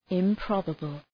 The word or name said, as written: improbable